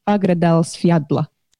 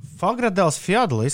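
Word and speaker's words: Fagradalsfjall: Jā, taisnība ir, bet es uh, mēģināju no rīta patrenēties šī vulkāna nosaukuma izrunāšanā. Agradels Fjadla esot tas. – Fagradēls Fjadla izklausās daudz vieglāk nekā tas Vulekuļs, kurš izvirzījās pirms pāris gadiem.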